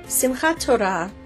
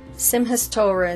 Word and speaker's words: Simchat Torah: Simchat Torah, Simchas Torah in the Yiddish pronunciation, means rejoicing in the Torah.